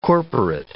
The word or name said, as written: corporate